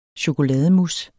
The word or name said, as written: chokolademousse